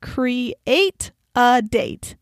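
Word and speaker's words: CRE8AD8: CRE numeral 8, AD numeral 8, which is pronounced create a date.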